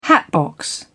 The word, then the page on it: hatbox: British English